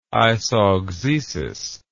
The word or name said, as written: isauxesis